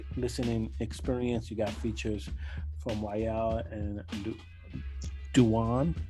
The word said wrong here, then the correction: Dawood: Did I say it right? I don't want to put Dawood. – Dawood.